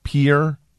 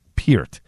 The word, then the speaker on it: Peart: P-I-E-R-T, Peart, Peart. Because a lot of people got Neil Peart's name wrong when he passed away.